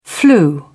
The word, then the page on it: flu: British English